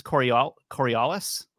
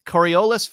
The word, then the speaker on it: Coriolis: Coriolis? Coriolis forces steer atmospheric currents to the right in the northern hemisphere and to the left in the south. So at the equator, where there are no Coriolis forces, different factors are at play.